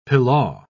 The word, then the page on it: pilaw: British English